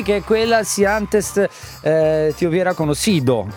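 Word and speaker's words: Si antes te hubiera conocido: Si antes ti hubiera conosciuto.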